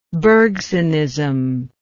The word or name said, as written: bergsonism